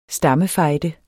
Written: STAMMERNE

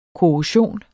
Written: korrosion

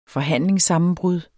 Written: forhandlingssammenbrud

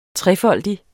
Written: trefoldig

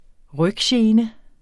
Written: ryggene

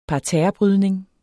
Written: parterrebrydning

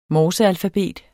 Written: MORSEALFABET

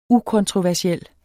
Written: ukontroversiel

